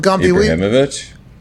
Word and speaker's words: Ibrahimovic: Do you call him Imbramovich? – Ibrahimovic? – Yeah. – I said it right.